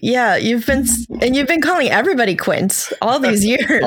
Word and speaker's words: Quint: I always thought I would pronounce cunt. Is Quint? – It's Quint.